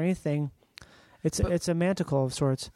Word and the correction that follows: manacle: You mean a manacle? – Yeah, a manacle. – I thought you said manticle. – I think I did. I think I said it wrong.